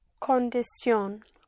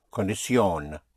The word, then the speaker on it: condición: Words ending in I-O-N in English are the same in Spanish, and T-I-O-N in English is spelled with C-I-U-N because that's how you pronounce it. So you don't have the shin in Spanish, but sion, yes, C-I-U-N. Like condition would be? – Condición. – Condición. Condición. The condition? – La condición. – La condición.